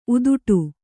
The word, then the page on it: ಉದುಟು: kannada